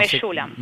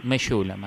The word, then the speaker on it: Mechoulam: No, Mechoulam. – Mechoulam, así se dice. – Bien. Sí, después se escribe Mechoulam, pero es Mechoulam.